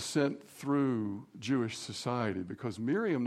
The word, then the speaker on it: Miriam: They started naming their daughters Miriam. That's the Hebrew pronunciation, but the English pronunciation is Mary.